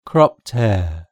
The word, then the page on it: cropped hair: British English